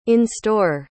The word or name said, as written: in-store